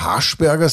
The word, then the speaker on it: Haschberger: Und jetzt dürfen wir trotzdem Haschberger sagen, weil das klingt ja wie Haschisch. Mhm. Aber wir betonen halt einmal hier in Vorarlberg auf der ersten Silbe und vielleicht hat sie dich drum.